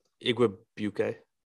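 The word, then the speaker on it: Igwebuike: Godwin Aguique, how do you say his name again? – Igwebuke. – That was beautiful. Okay, maybe that was right.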